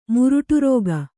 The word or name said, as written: ಮುರುಟುರೋಗ